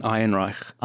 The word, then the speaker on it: Ehrenreich: Alden Ehrenreich.